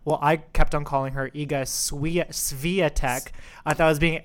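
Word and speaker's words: Świątek: However, we have recently been educated, and we now know how to pronounce the women's draw's new French Open champion. – And it's not Zachary. Whose name we were also mispronouncing for Evs. – That is correct. Zachary did not win. It is our new Polish phenom, Iga Swiatek.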